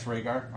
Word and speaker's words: Rhaegar: So Rhaegar, I guess Rhaegar, I'm fine with that pronunciation, they killed him and took over the kingdom from him.